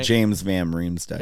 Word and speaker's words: James van Riemsdyk: So James Van Riemsdyk. Am I saying that right? – James Van Riemsdyk. – Van Riemsdyk. Mm-hmm.